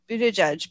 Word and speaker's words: Buttigieg: Buttigieg being nominated as transportation secretary.